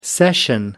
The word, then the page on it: session: American English